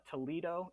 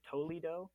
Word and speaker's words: Toledo: Like there's another town in Ohio called Toledo is how we correctly pronounce it, but there's a lot of people that would just pronounce it Toledo,